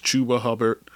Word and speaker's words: Chuba Hubbard: I've been saying his name wrong all this time. It's Chuba Hubbard.